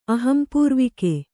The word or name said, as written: ಅಹಂಪೂರ್ವಿಕೆ